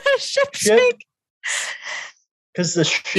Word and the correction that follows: sank: You mean the ship sank? – Sank.